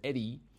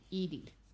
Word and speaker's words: Edie: I remember that Falco, 61 across, Falco of the Sopranos was Eddie. – Edie.